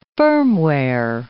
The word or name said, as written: firmware